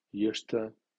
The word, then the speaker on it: used to: Used to, used to, porque "d" más "t" suenan como único sonido "t" en este caso al preceder "s" sorda.